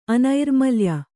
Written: ಅನೈರ್ಮಲ್ಯ

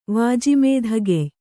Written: ವಾಜಿಮೇಧಗೆಯ್

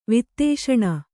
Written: ವಾತಾಶನ

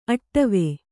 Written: ಅಡುವು

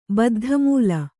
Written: ಬದ್ಧಮೂಲ

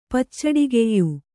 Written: ಪಚ್ಚಡಿಗೆಯ್ಯು

